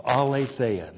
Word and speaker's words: Aletheia: The Spirit of Aletheia. A-L long E-T-H-E-I-A. Aletheia.